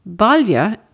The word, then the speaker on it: Baile: Balia is the Irish for town or home, and this is spelt B-A-I-L-E, and it appears in many of our townlands, villages, and town names.